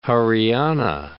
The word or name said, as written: Haryana